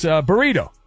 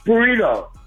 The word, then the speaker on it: burrito: It's Burrito. You know. – Burrito. – Yeah. All right. – Okay. – Don't forget to roll your R's.